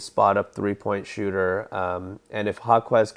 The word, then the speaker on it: Jaquez: So one thing that also stuck out to me was Jaime Jaquez. Is that how you say his name? – Yeah. That's close. Jaquez, yeah.